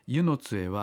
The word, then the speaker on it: 温泉津: 温泉津と書いて何て読むんでしたっけ。 – 湯の津です。 – 湯の津というふうに書いてありますね。温泉津って書いて、湯の津へようこそおいでくださいました。我が母の里湯の津へは物心つく頃から毎夏行っていました。